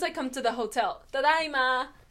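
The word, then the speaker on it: tadaima (ただいま): Oh, because it's AI, so it's tadaima. – Tadaima. – Tadaima. – Tadaima. – Ai. – Ai. – Tadaima. – Tadaima. – You pronounce both of them, which is not something you do in English or in Spanish. Or in French.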